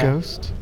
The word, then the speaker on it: ghost: It is, but like it's a silent H, isn't it? – Is it? – Yeah. – Ghost? – Ghost, G-H-O-S-T.